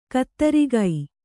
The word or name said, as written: ಖದಿರಕ